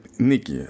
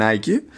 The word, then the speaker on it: Nike: Το, το, το σωστό στα ελληνικά είναι Nike. Απλά το σωστό κατά την εταιρεία είναι Nike. Γιατί προτιμούν το το Erasmian pronunciation, το σπίτι τη μέσα.